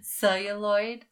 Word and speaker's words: celluloid: Celluloid. Is that how you say it? – Yeah, celluloid. Like it's the material that film is made with.